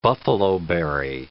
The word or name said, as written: buffalo berry